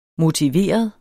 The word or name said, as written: motiveret